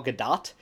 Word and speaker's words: Gadot: Apparently her name is pronounced Gal Gadot, which is different than what any of us clearly would have assumed. Because we've all read or seen Waiting for Godot, so we're like, Godot is, G-O-D-O-T is pronounced Godot.